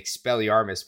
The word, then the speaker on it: Expelliarmus: He still said it wrong. – Expelliarmus. – For all of y'all who don't know, which is everybody except us, our producer Meg had to remind how to say Expelliarmus before this. And was like, I know how to say it, and then said it wrong anyway.